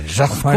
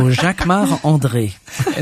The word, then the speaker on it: Jacquemart-André: You say it like André. – A Frenchman. – Jacquemart-André. Of course, because I'm French. So Jacquemart-André.